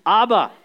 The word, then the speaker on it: Abba: ABBA, we pronounce this ABBA.